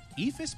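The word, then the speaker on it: Eephus: Who was the first player who developed and threw the Ephus pitch or Ephus pitch? Am I saying that right, Jesse? – You were saying that correctly.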